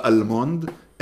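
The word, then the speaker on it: almond: שאין אל לא במילה סלמון ולא במילה אלמונד, אין אל. – אם האל באמצע לא אומרים אותה.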